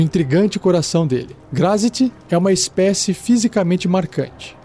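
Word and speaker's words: Graz'zt: Grazit, que se escreve G-R-A-Z apóstrofe Z-T. Grazit.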